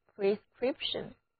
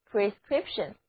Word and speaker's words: prescription: 同样的，我们也把 PRE 这个前缀加在另外一个词根 SCRIPT 的前面，最后再跟一个小尾巴 ION 这个名词后缀，得到的单词就变成了 prescription prescription prescription，两个字母 i 和字母 e 都读作短的 e，重音点，也是名词，指医生开的药方或者说是处方药。